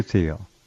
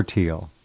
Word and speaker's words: Thiel: Peter Thiel. Or Thiel. It's pronounced both ways.